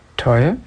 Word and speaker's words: Tuara: Tuara, is that how you pronounce it? T-U-A-R-A? Tuara? Tuara? – Tuara, is it? T-U-A-R-A. – Tuara. – T-U-A-R-A.